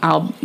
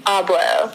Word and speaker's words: Abloh: Yeah, fashion itself and Virgil Abloh. Wait, how do I... Did I say his name? Abloh.